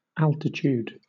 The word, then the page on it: altitude: British English